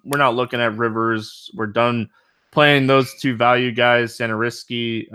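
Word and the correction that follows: Satoranský: Satoransky, or whatever the heck his name is. Satoransky, yeah, whatever. I'm looking right at his name, and I was still saying it wrong for some reason.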